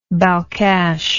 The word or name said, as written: balkhash